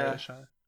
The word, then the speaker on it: Talia Shire: Talia Shire? Is that how you say it? Talia? – Oh, Talia Shire, yeah. Talia Shire.